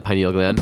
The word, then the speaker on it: pineal: And so it's the pineal gland. Am I saying that right? – Right. Yeah, the pineal gland. – Pineal gland. – Some people say pineal, whatever.